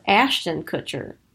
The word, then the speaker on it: Ashton Kutcher: I thought it was Ashton Kutcher. – It really is. I just said it wrong.